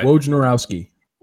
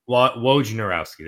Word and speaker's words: Wojnarowski: So if the NBA comes back, according to Adrian, well, Woj, as everybody knows him, I can't pronounce his last name because I'm dumb. But Wojnarowski. – Wojnarowski, there we go.